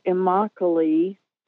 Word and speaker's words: Immokalee: And then when I got older, I got married and moved to Florida, and not far from a community called Immokalee, which rhymes with broccoli, if in case you want to remember it.